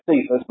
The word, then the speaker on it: Cephas: Some people pronounce that Cephas, but it's a hard C really, but you know, I'm not going to argue with her over that. Whether you call it Cephas or Cephas, it's Peter.